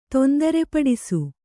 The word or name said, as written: ತೊಂದರೆಪಡಿಸು